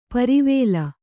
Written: ಪರಿವೇಲ

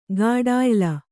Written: ಗಾಢಾಯ್ಲ

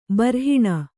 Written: ಬರ್ಹಿಣ

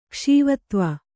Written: ಕ್ಷೀವತ್ವ

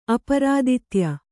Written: ಅಪರಾದಿತ್ಯ

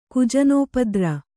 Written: ಕುಜನೋಪದ್ರ

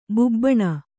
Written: ಬಾಬೂನ

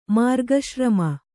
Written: ಮಾರ್ಗಶ್ರಮ

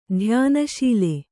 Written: ಧ್ಯಾನಶೀಲೆ

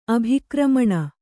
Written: ಅಭಿಕ್ರಮಣ

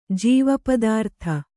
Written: ಜೀವಪದಾರ್ಥ

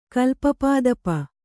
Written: ಕಲ್ಪಪಾದಪ

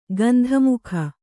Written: ಗಂಧಮುಖ

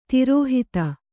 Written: ತಿರೋಹಿತ

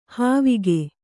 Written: ಹಾವಿಗೆ

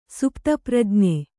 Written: ಸುಪ್ತಪ್ರಜ್ಞೆ

